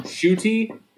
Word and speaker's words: Ncuti: Shuti is how you pronounce his name. – It's the N-C-U-T-I, right? Yeah. – Yeah. It's pronounced Shuti.